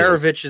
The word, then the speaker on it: Maravich: Maravich is the correct pronunciation.